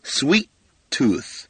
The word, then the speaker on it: sweet tooth: 最后的 th 也咬舌头 tooth 啊，两个 t 在一起的时候，第一个 t 就不发音了 sweet tooth, sweet tooth.